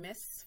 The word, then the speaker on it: Mss: Okay, so as you said, I'm Miss Francois, that's spelled MSS for multi-talented and super sexy.